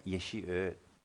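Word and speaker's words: Yeshe Od: And he had received permission from the abbot at the request of the king of Tibet, Yeshi Ud. O-D. Yeshi Ud. That's how they spell it. And Yeshi is Y-E-S-H-E.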